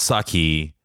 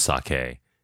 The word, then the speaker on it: sake: it's not sake. I know it's not sake. I don't know why I was calling it sake. I know it's sake.